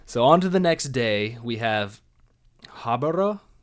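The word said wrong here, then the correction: Haibara: I had Hibara. – Hibara? – Because it's H-A-I.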